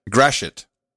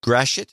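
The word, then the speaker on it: Gratiot: There's a pronunciation for this. Graciot. I'm not sure. – It's Graciot. – Graciot? – Graciot. Okay. Yep. – Graciot. – I was told to remember it by just remembering it's grass shit.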